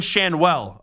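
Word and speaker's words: Schanuel: That was a spectacular catch by center fielder Denzel Clark, who robbed Angels first baseman Nolan Schnell. How do you say that last name? Nolan Schanwell of a home run last night with one of the greatest catches that you'll ever see.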